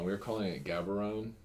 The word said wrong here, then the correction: Gaborone: for yeah. a while. And it's Habarone.